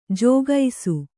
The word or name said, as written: ಜಾಗಿಸು